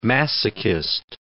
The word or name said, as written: masochist